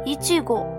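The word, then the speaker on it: 一護: 那我们再回到 Kurosaki Ichigo 黑崎一护，那么他的名字 Ichigo 啊，Ichigo，他和草莓的发音是完全一样的。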